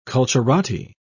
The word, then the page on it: culturati: British English